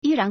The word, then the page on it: 依然: yīrán